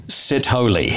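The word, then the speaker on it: Sithole: Now, it's S-I-T-H-O-L-E, but it's pronounced Sitholi.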